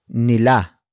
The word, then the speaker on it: nila: The last word in this sentence is nila. Nila. Nila. Nila is spelled as N I L A.